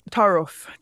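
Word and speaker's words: ta'arof: and I may be mispronouncing it. Ta'aruf. – Taruf. Taruf.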